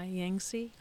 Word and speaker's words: Yancey: Y A N C E Y, Yancey? – I think so, Yancey.